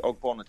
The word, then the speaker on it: Ogbonna: It was just Mbogna. Is that his name? I don't even know how to say his name. – Ogbonna. Yeah, West Ham. Ogbonna for West Ham.